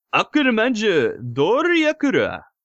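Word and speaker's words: Akumajo Doryakura (悪魔城ドラキュラ): No, it's Akumajo Doryakura. That's how you say it, I believe.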